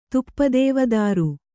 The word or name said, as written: ತುಪ್ಪದೇವದಾರು